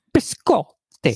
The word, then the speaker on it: biscotti: Biscotti.